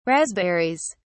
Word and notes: raspberries: British English

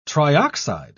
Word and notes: trioxide: British English

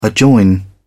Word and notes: adjoin: British English

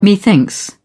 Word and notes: methinks: British English

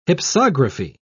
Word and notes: hypsography: British English

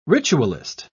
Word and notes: ritualist: British English

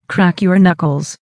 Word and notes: crack your knuckles: American English

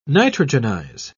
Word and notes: nitrogenize: British English

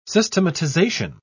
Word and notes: systematization: British English

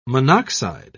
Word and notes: monoxide: British English

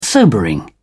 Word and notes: sobering: English; British English

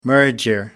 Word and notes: merger: American English

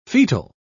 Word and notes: foetal: British English

